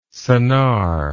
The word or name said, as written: Sennar